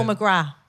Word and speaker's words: McGrath: Paul McGrath. Yeah, we don't pronounce the TH.